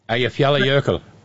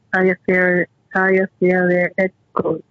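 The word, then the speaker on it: Eyjafjallajökull: So how do you say it again? – Ayafyalayokul. – Okay. Ayafyalayokul. Okay. Okay. So I more or less, what gets me, the reason that I think of the Aya or Aya, I say Aya, Aya, and that's what, once I get that one down, that first couple of syllables, then I can say the rest of it.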